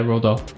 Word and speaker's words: Roald Dahl: Yeah, Roald Dahl.